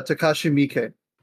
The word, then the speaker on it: Takashi Miike: Takashi Miike.